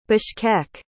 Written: Bishkek

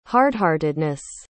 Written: hardheartedness

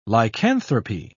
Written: lycanthropy

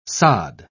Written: sod

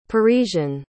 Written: parisian

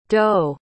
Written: doh

